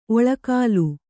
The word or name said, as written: ಒಳ್ಗಾಲ